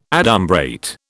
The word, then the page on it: adumbrate: British English